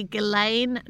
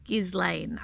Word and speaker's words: Ghislaine: Definitely Ghislaine, not Ghislaine, as we discussed on Wednesday. – The S is silent, I think, because it's French.